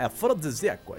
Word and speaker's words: afrodisíaco: Como é que fala? Afrodíticos. afrodíticos. – Afrodítico. Afrodisíaco. – Afrodite, é afrodisíaco, é isso aí.